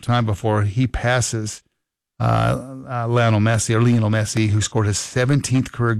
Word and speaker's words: Messi: By the way, if you are a soccer fan, Lionel Messi, or Lionel Messi, I was told that's how you pronounce it.